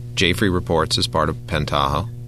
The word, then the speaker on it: Pentaho: Now, here's one that you've really pushed in the limit, which is, I'm going to say this wrong, Pentaho, exactly.